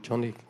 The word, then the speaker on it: Joni: It's called Johnny and Friends. – Könnt auf der Webseite unter Johnny and Friends nachschauen. – It's spelled Joni, but it's pronounced Johnny.